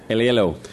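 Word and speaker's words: Aiello: Aliello? – Aliello.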